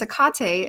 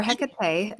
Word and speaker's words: Hecate: and then there's Hecate as well, or Hecate, depending on how you want to pronounce it.